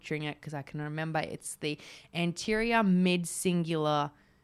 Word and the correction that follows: cingulate: cortex. Let me see if I got that right. AMCC. The anterior mid cingulate cortex. Okay, close he said this is the area of the brain that is linked to willpower.